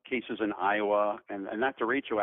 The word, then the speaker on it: derecho: Last year there was an event that I had never heard of in Iowa, and I'm going to try not to mispronounce it, a derecho. – Yeah, derecho. – Derecho, close.